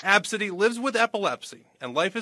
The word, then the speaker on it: Abcde: Tracy's 5-year-old daughter has a unique name spelled ABCDE, but it's pronounced Absidy.